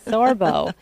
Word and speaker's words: Sorbo: Yes, thank you, Sorbo.